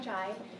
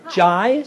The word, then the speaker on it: Jai: Oh, hi, I'm Jai. – Hi. – Jai? – Jai, yes. – Jai. How do you spell that? – J-A-I. Okay.